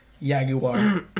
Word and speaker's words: Jaguar: And I think it's pronounced Jaguar. – Yeah, Jaguar. – I think it's Jaguar. – I think it's Jaguar. – You gotta say it with a yay, Jaguar.